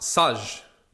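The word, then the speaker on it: Saj (سج): The word we now have to determine a combination, or is a combination of S and J, pronounced Saj, means to plaster or coat, as with clay.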